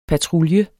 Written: patrulje

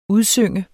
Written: udsynge

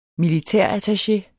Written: militærattaché

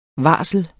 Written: varsel

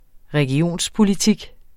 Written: regionspolitik

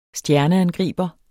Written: stjerneangriber

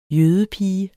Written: jødepige

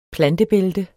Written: plantebælte

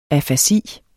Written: afasi

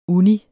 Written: uni

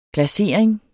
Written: glasering

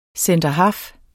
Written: centerhalf

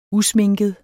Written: usminket

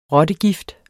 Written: rottegift

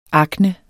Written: akne

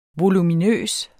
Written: voluminøs

